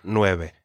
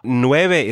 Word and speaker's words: nueve: Nueve is also only 2 syllables. So nueve. Now, the first syllable, nue, is sometimes hard for English speakers to say in 1 syllable, right?